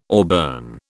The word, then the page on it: auburn: British English